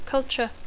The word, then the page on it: culture: English